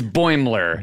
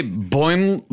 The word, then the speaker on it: Boimler: It's Boimler. – Boimler. – But some people say Boimlier or like Boimier.